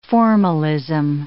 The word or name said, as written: formalism